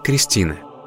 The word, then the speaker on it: Христина: В современном русском языке имя Христина обычно произносится как Кристина.